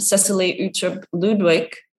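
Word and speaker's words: Cecilie Uttrup Ludwig: And fun fact, 1 year ago, that's when Cecily Utrecht Ludwig, did I say it wrong? – No, perfectly.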